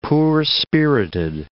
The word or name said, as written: poor-spirited